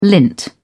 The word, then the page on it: lint: British English